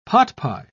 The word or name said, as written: potpie